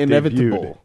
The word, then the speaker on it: inevitable: Well, but you're supposed to not pronounce the T as hard. That's the whole point with inevitable. – Debuted. – You don't like that? No. – Inevitable. – Inevitable. Like vegetable. – It's a silent T.